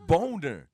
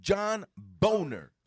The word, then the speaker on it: Boehner: Eh, Boehner. John Boehner. It's Boner. It's John Boner.